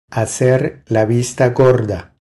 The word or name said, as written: Hacer la vista gorda